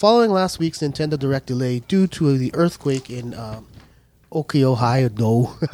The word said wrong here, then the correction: Hokkaido: How do you pronounce that? – Let's do this. – I can't pronounce it. Hokkaido.